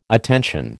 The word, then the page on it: attention: American English